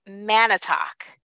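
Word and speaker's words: Manitoc: I think it's Lake Manitowoc, like M-A. I think I was saying like M-O.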